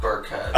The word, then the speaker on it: Burkhead: Burkhart? – Fuck Burkhead.